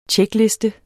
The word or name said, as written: tjekliste